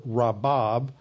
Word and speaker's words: rabab: The increased is from the verb rabab, And the noun is the translated the word many, that is Rav. So one is R-A-B-A-B, pronounced, the B is pronounced soft like a V, and the other one is just R-A-B.